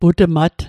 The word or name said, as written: putmat